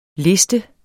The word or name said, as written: liste